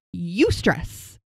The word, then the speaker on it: eustress: But first, let me talk to you about eustress. It's spelled E-U-S-T-R-E-S-S. So the word stress, but it has the words E-U in front of it.